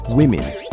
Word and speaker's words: women: Now, if you take the word women and listen to the letter O as you pronounce it, it sounds like an I, women.